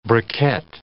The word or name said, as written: briquet